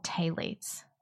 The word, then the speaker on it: phthalates: I mean, I've done a lot of research on, I think they're called phthalates, spelled like phylates, PH, but they're just in everything.